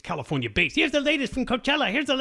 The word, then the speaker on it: Coachella: You went to Coachella? No. – No, it's Coachella. I like to mispronounce it like Tijuana.